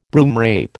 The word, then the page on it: broomrape: American English